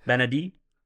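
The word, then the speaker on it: Bennedict: Benedict. Don't pronounce the T. – Benedict.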